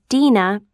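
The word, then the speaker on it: Dena: S-T-E-V-E-N. – 2. – Hi, my name is Dina Brown. – Hello. How do you spell your first name? – It's D-E-N-A.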